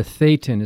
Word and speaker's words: Thetan: You're a Thetan. Is that how you pronounced it? – Yes.